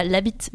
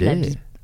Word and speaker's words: Labit: Le musée Georges Labit, et non pas Labit, malgré le T. Et malgré que normalement à Toulouse, on prononce toutes les lettres.